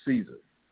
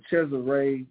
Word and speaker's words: Cesare: Cesare, we call him Caesar. I know y'all here probably hear Israelites saying Caesar Borgia. Okay, but Cesare Borgia, that's how you pronounce his name.